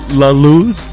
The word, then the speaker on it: La Luz: Before that, we had La Luz.